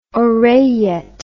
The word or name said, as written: oreillette